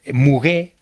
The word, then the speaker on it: muguet: crece una flor que se llama muguet. Sin pronunciar la T final en francés,